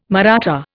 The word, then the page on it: Maratha: Indian